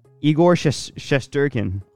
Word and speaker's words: Igor Shesterkin: How do you say the guy's name? – Igor Shesterkin.